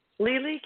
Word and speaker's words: Lili: Lily, California. – Lily, pretty name. How do you spell it? – Thank you. L-I-L-I.